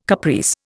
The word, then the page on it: caprice: Indian